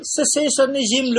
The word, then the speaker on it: cessationism: Patama ka ro cessationism lu kho de. A ra ka comes from the English word like ceasing, stopping. Cessationism. Yeah, accent on the second syllable.